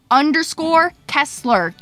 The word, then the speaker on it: Kesler: _Kessler, K-E-S-L-E-R.